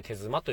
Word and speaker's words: 手妻: えー、手妻というのは、えー、まあ、手足の手に、えー、妻、え夫と妻の妻ですね。えこれで手妻と読むんですけども、まあ、すごく雑に言ってしまえば、言ってしまえば、まあ、日本式マジックということですよね。